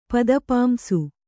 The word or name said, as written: ಪದಪಾಂಸು